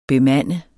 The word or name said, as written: BEMANDE